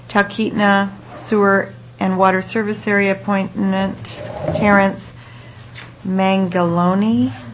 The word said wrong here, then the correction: Mangioni: Did I get that right? – Mangioni. – Mangioni.